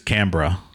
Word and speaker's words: Canberra: I looked it up. I believe it's Canberra. – Canberra. – Canberra. – Of all the words we've butchered on this show, that one, not so awful. That was fairly close. – I mean, I said it how it's spelled, right? – Yeah, it seems like they just kind of remove the E.